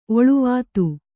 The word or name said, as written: ಒಳುವಾತು